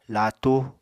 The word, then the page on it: laatu: Apatani